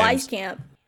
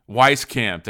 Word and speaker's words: Wieskamp: Is it Wieskamp? I'm so bad with names. – Oh, Wieskamp. – Wieskamp. There you go.